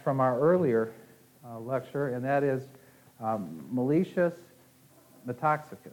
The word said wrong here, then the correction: Metaxakis: Is that right? Pronunciation? Metaxakis. Okay. Thank you.